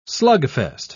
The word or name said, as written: slugfest